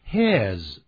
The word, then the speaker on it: his: 这个时候我们用 his，他的，表示男孩子的他的。念这个字咱们中国人啊，有时候也有点毛病，会念成 his，不要念成 his，这是一个 his it 的音，记住，而不是 e 的音。